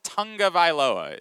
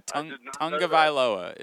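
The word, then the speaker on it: Tagovailoa: Yeah, Talia Tungavailoa is how he pronounces it. There's an N in there that you don't see when you read it. – I did not know that. – Yeah, Tungavailoa is how he pronounces his last name.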